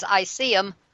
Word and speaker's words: ISSEEM: but when you pronounce it, it's ICEM, and so that's kind of an interesting thing, too.